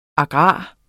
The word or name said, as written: agrar